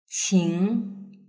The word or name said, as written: qing